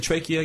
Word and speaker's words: trachea: How did you say trachea again? – Trachea. It's the original Greek pronunciation.